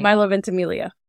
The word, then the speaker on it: Ventimiglia: Milo Ventimiglia.